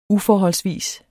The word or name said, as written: uforholdsvis